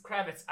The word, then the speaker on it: Kravitz: Yeah, this is a Zoe Kravitz. – Kravitz.